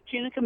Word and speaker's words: Tunica: So you were raised in the Mississippi Delta town of Tunica? Is that how you pronounce it? – That's right, Tunica, Mississippi.